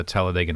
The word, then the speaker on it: Talladega: Yeah, and we talked about that a little bit with the Talladega Nights. – The emphasis is on the E.